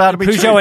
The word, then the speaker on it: Peugeot: Peugeot. – Coming back to the US, and that came out yesterday, and it was like, it's just April Fools. – That's what we thought. So Peugeot announced, and they said, we're coming back to the US, and we're going to come back 2 years ahead of schedule, yeah.